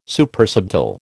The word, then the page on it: Supersubtle: American English